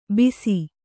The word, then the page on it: ಬಿಸಿ: kannada